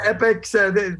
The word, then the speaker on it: Epix: Hey, by the way, I've got a documentary coming out on Epix, is that how you pronounce it, Epix channel? – You got it. – Epix? – Yeah, Epix this weekend, I think, 10 o'clock.